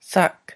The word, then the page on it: sak: Lwaitel